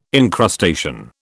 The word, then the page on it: incrustation: British English